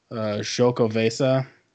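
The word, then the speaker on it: Xocoveza: I just, while you were talking, I was looking up how to pronounce it. It's Stone Chocovesa.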